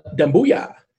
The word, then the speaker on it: Doumbouya: What is it? Let's pronounce it. – Oh, Doumbouya.